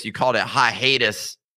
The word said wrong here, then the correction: hiatus: Hiatus. – It's a dirty South pronunciation of hiatus.